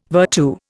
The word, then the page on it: virtu: Indian